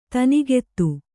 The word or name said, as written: ತನಿಗೆತ್ತು